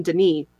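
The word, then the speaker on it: D'ni: They have trapped him in Dunny, as it's pronounced in the game, where the green book leads, by taking a page from his Myst linking book so that he could not return.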